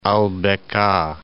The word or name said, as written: Al Biqa'